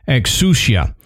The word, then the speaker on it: exousia: So the word exorcism actually derives from the Greek word for oath, which is exousia. I Google pronounced that, so I hope they told me that correctly. But I think you did a good job on that one.